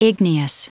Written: igneous

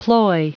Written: ploy

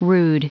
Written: rude

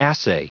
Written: assay

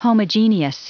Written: homogeneous

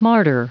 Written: martyr

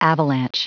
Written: avalanche

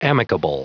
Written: amicable

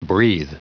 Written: breathe

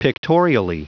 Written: pictorially

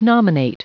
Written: nominate

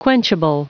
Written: quenchable

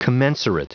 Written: commensurate

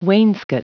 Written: wainscot

